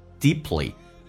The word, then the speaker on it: deeply: To dive deeply， dive I 这个发音稍微饱满一点，上下打开到极限，再滑动到 E 这个发音叫 dive，潜入，潜水 deeply，潜得很深 deeply， P 跟 L 在一起， P 可以弱化，可以不发音叫 deeply， deeply 就好像 exactly 说成 exactly，那么足够的有勇气去潜入的更深。